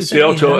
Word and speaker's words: Scioto: Is it Scioto? Is that how you... – Scioto in Ohio, yeah, that's where Jack... – That how you pronounce it, and that's why I think Nicklaus has won at Oak Hills, and of course, famously, Jack Nicklaus grew up in playing Scioto.